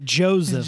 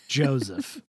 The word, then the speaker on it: Joseph: Joseph. – Joseph. – I hate that. I just tweeted that like 3 days ago. I think I tweeted like, what's with pronouncing the S in Joseph like a Z?